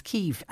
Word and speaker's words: Kyiv: which is Kyiv.